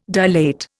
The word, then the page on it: dilate: Indian